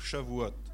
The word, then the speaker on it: Shavuot: Moi j'ai regardé sur internet la prononciation, j'essaye de me documenter, c'était Shavuot avec le O qui descendait. Eh bien alors va pour Shavuot, si vous voulez.